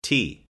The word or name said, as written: tea